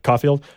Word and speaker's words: Caulfield: I'm assuming it's pronounced the same Caulfield. – Caulfield. But it's spelled differently. There's an L in the middle, another L in the middle. Caulfield.